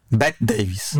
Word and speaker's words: Bette Davis: Znaczy, ja muszę przyznać, że Betta Davis... – Bette Davis. – No teraz tak, ale powiedziałeś Betta Davis.